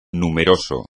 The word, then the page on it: numeroso: espagnol